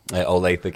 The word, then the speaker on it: Olathe: Olathe, Kansas. – Olathe.